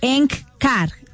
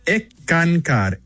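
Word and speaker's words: Eckankar: Y hablábamos de que eres miembro de ENCCAR, ¿no? – Se pronuncia ECANCAR.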